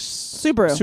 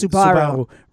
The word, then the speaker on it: Subaru: Suddenly, we're in our Subaru, or is that how you say it in English? – Subaru. – Right, in Israel, it's Subaru.